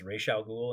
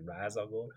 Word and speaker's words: Ra's al Ghul: It's funny because that also reminds me of Batman, where they pronounce, it's supposed to be pronounced Ra's al Ghul, and they keep pronouncing it Ra's al Ghul.